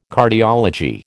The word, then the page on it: Cardiology: American English